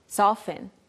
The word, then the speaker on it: soften: Soften, soften. There's a T there, but usually we just say soften.